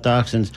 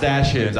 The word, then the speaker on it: dachshunds: This piece is about dachshunds, although, as you quickly hear, this comic doesn't pronounce it that way. This is Rocky Dale Davis with a bit called Killer Dachshunds, my pronunciation, not his, in today's comedy corner on Talking Animals on WMNF.